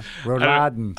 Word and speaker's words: Rouladen: Yeah, Rouladen.